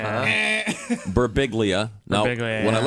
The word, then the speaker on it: Birbiglia: Joining us in the studio, comedian Mike Birbiglia.